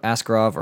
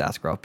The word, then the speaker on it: Askarov: As far as not getting Yaroslav Askarov, or however the hell you say his name, I'm pretty sure it's Askarov.